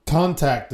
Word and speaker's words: contact: Contact the dead. Did I say it right that time? – Yeah. – Contact. What did I say before? – Contact. You have the emphasis on the wrong syllable.